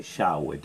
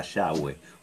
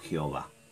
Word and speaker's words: Yahweh: En el hebreo, recuerden que alguna vez lo hablamos, no hay vocales, entonces usan solo las consonantes y eh, cuando vos pronuncias las consonantes corridas en hebreo te sale Yahweh, te queda Yahweh o llave o llave según como, lo quieras pronunciar. Eh, y, nosotros digamos los, la gente que no es del pueblo hebreo, que se ha convertido al Señor, ¿eh? Eh, eh, lo pronunciamos Jehová.